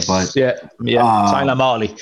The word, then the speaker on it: Mahle: You know, Tyler Mahill, I don't know how to say his name, Tyler Mahle.